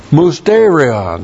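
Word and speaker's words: mysterion (μυστήριον): The first authority is the Word of God, with emphasis on mysterion, that's the Greek word in the New Testament. Looks like this in the Greek. That's M-U-S-T, long E-R-I-O-N.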